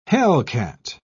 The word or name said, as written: hellcat